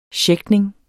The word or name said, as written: schæchtning